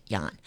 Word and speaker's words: Ian: If you didn't hear the name of that fabulous artist that I just had, it's Ian, but some people Maxkin, but some people say Ian because it's spelled I-A-N, but he pronounces it, and I guess most people in the world would pronounce it Jan.